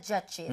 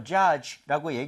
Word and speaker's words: judge: D 사운드를 약하게 해서요. judge. 그리고 마지막에 입 모양이 하고 모여야 됩니다.